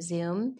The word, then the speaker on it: Zume: It was spelled Zume Pizza, but we pronounced it Zoom.